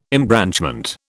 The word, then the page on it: embranchment: British English